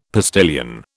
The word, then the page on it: postilion: British English